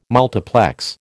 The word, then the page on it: multiplex: American English